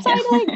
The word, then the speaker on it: Seidling: We have Janine Seidling. Oh my gosh, did I say that right? – Yeah, you did. Yeah, Seidling.